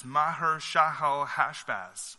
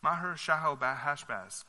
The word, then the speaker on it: Maher-Shalal-Hash-Baz: It's Maher-Shalal-Hash-Baz. Maher-Shalal-Hash-Baz.